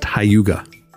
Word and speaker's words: hygge: It's spelled H-Y-G-G-E, but it's pronounced Hyuga.